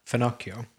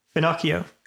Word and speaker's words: finocchio: Finocchio. – Yeah, there you go. I wasn't quite sure how you pronounce it. Finocchio.